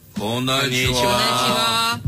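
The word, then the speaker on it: こんにちは: Konnichiwa。 – Konnichiwa。 – 哎，发音简单点，第一是空啊，M 空空的空。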